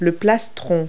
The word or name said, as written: plastron